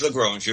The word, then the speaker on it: Lagrange: And again, Lagrangian points, Lagrange, how was he pronounced? L-I-G-R-I-N-G. – Yeah, no, it's Lagrange, you're right.